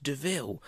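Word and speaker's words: de Vil: It's like, it's spelt devil, but pronounced DeVille.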